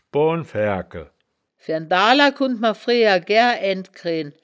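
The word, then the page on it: schboanfaergkel: Wittlicher; schboan-faergkel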